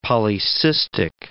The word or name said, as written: polycystic